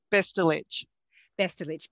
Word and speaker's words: Bestulic: Bestulik.